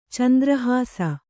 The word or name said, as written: ಚಂದ್ರಹಾಸ